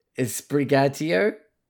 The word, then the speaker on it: Sprigatito: It's Sprigatito. – Sure. – Sprigatito. – Yeah, I don't know. – Sprigatito. Yeah, okay. You say it in an Italian accent, like a Spanish accent, maybe. – Sprigatito.